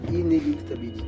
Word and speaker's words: inéluctabilité: Inéluctabilité.